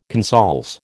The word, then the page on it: consols: American English